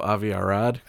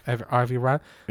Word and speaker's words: Avi Arad: Avi Arad... Is that how you say it? – Oh, Avi Arad. – Avi Arad.